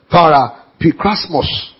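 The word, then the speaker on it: Parapikrasmos (παραπικρασμός): I'll spell it for you. P-A-R-A-P-I-K-R-A-S-M-O-S. Parapikrasmos. P-A-R-A-P-I-K-R-A-S-M-O-S.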